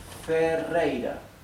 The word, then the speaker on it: Ferreira: This R in Ferreira, in my last name, is doubled. Therefore, it is pronounced R, rei, R. This last R is not doubled and is in the middle of the word, therefore it is pronounced R, right? The soft sound, ra, R, ra.